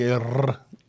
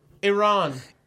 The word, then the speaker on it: Iran: Good to say like Iran. – No, I'm not rolling my eyes. Iran. – I can't do it for burrito. – Take another chip. Take another chip. You roll your R's.